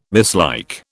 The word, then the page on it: mislike: British English